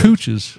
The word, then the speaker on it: Cooch's: Cooch's. – It like, you know, that's the Gooch's cousin. – It's spelled like coach, but with two O's. No A's.